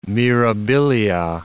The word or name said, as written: mirabilia